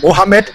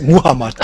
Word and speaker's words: Muhammad: Mohammed, er ist Muhammad, bitte. Nicht Mohammed. Wir können da riesigen Ärger kriegen. – Muhammad, also Englisch ausgesprochen.